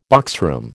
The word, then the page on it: boxroom: American English